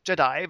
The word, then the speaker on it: Jedi: Irgendwie, Jedi Knight 2, wie spricht man das aus? Jedi? Genau, Jedi Outcast. Jedi wird das ausgesprochen.